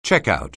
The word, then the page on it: checkout: English